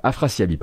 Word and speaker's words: Afrasiabi: Afriasabi, donc dont je, je parlais tout à l'heure. Je prononce bien son nom Est-ce que je prononce bien son nom Afrasiabi, pardon.